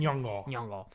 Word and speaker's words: Nyong'o: El personaje de Lupita Nyong'o, Nyong'o, no sé cómo se dice. – Nyong'o.